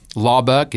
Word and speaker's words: Laubach: Rhett is R-H-E-T-T. Laubach is L-A-U-B-A-C-H.